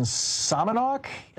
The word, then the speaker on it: Sammamish: Picking up your calls here, Patricia in Sammamish, Illinois. Am I saying that right, Patricia? – You are.